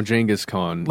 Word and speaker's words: Genghis Khan: In a previous episode of this show, we explored the strange, ongoing, and likely doomed quest to find the grave of Genghis Khan. And we also discovered that is the correct pronunciation. – Yes, Genghis Khan is now Genghis Khan. – Like Jenga, like the game.